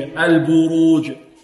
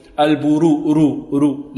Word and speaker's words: البروج: فهنا حكمها التفخيم، البروج البروج. ولكن نرقق الباء التي قبلها، البو البروج. نرقق الباء ونفخم ماذا؟ الراء، البروج. ويحذر الطالب من عدم إعطاء الراء حقها من التفخيم، لا ينطقها هكذا والسماء ذات البروج، البرو رو رو، لا، البرو البرو. البروج.